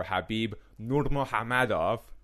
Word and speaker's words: Khabib Nurmagomedov: Khabib Nurmagomedov, and I swear to you that's how you pronounce the name, versus Justin Gaethje.